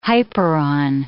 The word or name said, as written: hyperon